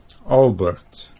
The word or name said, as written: Albert